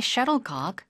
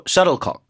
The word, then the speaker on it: shuttlecock: You only need a racket and a shuttlecock and you can play anywhere. – Shuttlecock 발음 도와드릴게요. – Shuttlecock. – 앞에 강세 주시고 shuttlecock. – Shuttlecock. – Shuttlecock. 콕이 아니라 컥에 가까워요.